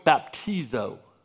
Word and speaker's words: baptizo: It's simply the word baptizo. That's how you say it.